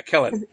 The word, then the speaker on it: Kellen: So I hope I'm saying her name right. I think it's Kellen, right? – Yeah. Yeah, Kellen. – Because it reminds me of Helen from my season, but with a K.